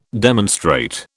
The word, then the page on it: demonstrate: British English